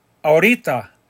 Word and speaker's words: ahorita: Right now, ahorita. Remember the H is silent. Ahorita.